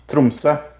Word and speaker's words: Tromsø: It says on Monday, arrested suspected Russian spy in an Arctic town of Tromos. Is that how you say it? Tromso? – Tromsø.